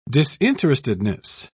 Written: disinterestedness

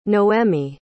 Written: noemi

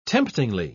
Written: temptingly

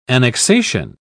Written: annexation

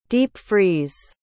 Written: deep-freeze

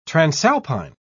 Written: transalpine

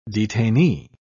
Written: detainee

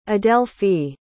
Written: adelphie

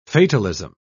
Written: fatalism

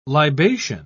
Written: libation

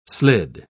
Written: slid

